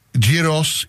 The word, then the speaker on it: gyros: But because you go there a lot, can you give us the proper pronunciation for gyros, gyros, gyros? – Gyros.